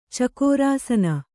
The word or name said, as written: ಚಕೋರಾಸನ